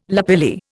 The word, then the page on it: lapilli: Indian